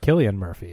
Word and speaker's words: Cillian: Does it have Cillian Murphy? – Is that how you say it? – Yes.